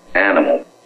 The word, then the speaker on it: Animal: Animal. – Can you spell that, please? – Yeah. A-N-I-M-A-L.